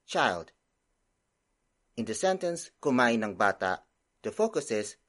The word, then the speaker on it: kumain: The first word is kumain. Kumain. Kumain. Kumain is spelled as K U M A I N.